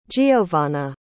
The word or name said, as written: jeovanna